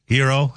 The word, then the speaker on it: gyro: And I did have a gyro. Hero, that's what I asked him how to pronounce it, and that's what he said. It's a hero. That's how he said it.